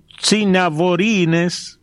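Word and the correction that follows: Sanavirones: agricultores y alfareros, no sé si lo dije bien, cinadirones, ahora sí, Franco, cinadirones, agricultores y alfareros.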